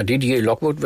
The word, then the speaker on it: Didier Lockwood: Und ja, einer der renommiertesten Jazzgeiger überhaupt, das ist wahrscheinlich, ich hoffe, es richtig ausspreche, Didier Lockwood. – Ja, Didier Lockwood, würde ich sagen, ist Lockwood, aber leider schon verstorben, also 2018.